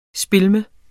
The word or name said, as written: spilleme